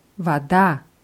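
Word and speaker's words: вода: Wada, ja. Hier ist die Betonung bei der Grundform auf dem A, Wada.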